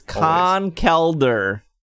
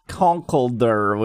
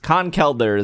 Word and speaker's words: Conkeldurr: It is Conkeldurr, not Conkeldurr, which is the wrong way that I've said for many years. Conkeldurr is how you say it.